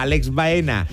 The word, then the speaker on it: Alex Baena: Y despierta cada día a Alex Baena y a Marc Pubill.